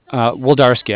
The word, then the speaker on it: Wlodarski: Woldarski.